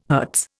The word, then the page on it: Hz: Indian